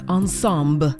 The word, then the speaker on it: ensemble: It looks like the word ensemble, but since it is French and I kind of heard her saying ensemble, I'm going to say it like Guyanse does, ensemble.